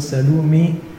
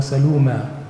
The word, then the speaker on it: Salome: طبعا بالإنجليزي تكتب S-A-L-O-M-E، فبرضو النطق بتاعها لو لاتيني أو إنجليزي ممكن سلومي أو سلومة على حسب النطق المكتوب فيها.